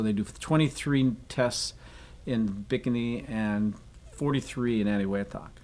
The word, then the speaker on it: Bikini: Most Americans here think of it as Bikini. – Mm-hmm. – Marshallese pronounce it Bikini. – Bikini.